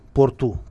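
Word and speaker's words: Porto: Был в Португалии, э, в городе Порто. Порту, правильно говорить, Порту. Там все на У и на Ше, Португалии.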